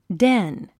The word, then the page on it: den: dén; デン